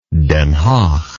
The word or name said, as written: Den Haag